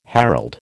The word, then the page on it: herald: American English